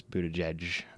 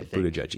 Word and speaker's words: Buttigieg: it used to be, I don't know, I don't remember, but I saw an article that in 2017 it was a different pronunciation, and now it is Buttigieg. – Yeah, Buttigieg. Easier, yeah.